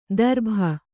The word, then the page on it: ದರ್ಭ: darbha